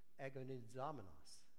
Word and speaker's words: agonizomenos: If you were to pronounce the Greek word, it's agonizomenos. Do you see the word agony in that Greek word?